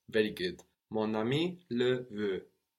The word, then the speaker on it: mon ami: We also notice how the N joins on to the next word. In sound, not in writing. Mon ami. It joins on to the next syllable rather than just really pronouncing the N more on mon. No? Mon ami. No, the N joins on to the next word. Mon ami.